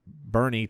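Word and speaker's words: Boerne: We've got Bernie, Texas, which is spelled just like Bourne Identity, but it's not the Bourne Identity.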